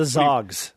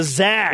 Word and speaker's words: Zags: It's not the Zogs. What are you from, the Zags.